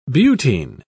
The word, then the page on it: butene: British English